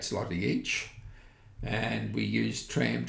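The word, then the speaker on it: złoty: Also, it's zloty. – Zloty, that'd be right, yeah. – So the L with a dash on it, which is super Polish, is pronounced like a W. So their currency is zloty.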